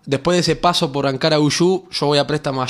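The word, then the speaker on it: Ankaragücü: primero Ankara Uyú es la pronunciación, que quiere decir la fuerza de Ankara.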